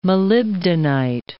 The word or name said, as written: molybdenite